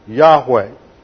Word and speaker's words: Yahweh: It is known by the sacred tetragrammaton, YHWH, and usually pronounced Yahweh.